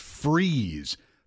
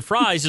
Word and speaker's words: Fries: And I will tell you, that is pronounced freeze. People do that all the time. – The reason why I pronounce it fries is because it's Friday.